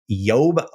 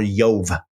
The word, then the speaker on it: Job: And by the way, the Hebrew pronunciation of Job would have been Yob or Yov. Yov. Okay.